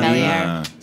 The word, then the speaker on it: Velier: Yeah, it's an Italian company, though. So you pronounce the R. Velier.